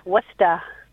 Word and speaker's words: Worcester: No, it's actually pronounced Worcester, as if, Massachusetts. – Worcester.